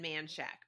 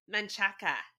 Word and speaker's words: Manchaca: So after, my tantrum is Kevin and Man Shack because Manchaca is how you say it.